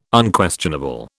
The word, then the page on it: Unquestionable: British English